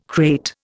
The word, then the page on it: crate: Indian